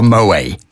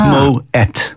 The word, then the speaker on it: Moët: Actually, I think one of the first things I ever learned on the slot was that it wasn't called Moët. Because a lot of people still probably call it that. You do pronounce the T. – Yeah, and well, there's the little two dots over the E. So you would that makes a two syllable word. So it's Moët.